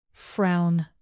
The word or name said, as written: frown